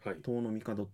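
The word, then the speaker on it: 遠の朝廷: 遠くの朝廷と書いて、遠野の帝と読みます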